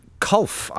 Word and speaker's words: Culph: Their lead goal kicker was Claude Culph, I believe is how you pronounce it, C-U-L-P-H, with 35.